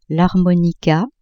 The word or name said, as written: harmonica